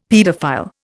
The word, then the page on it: paedophile: Indian